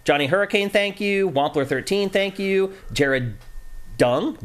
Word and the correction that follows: Young: thank you. – Jared Young, maybe. – Yeah, Young, you're right.